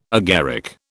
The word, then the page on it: agaric: British English